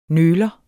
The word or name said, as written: nøler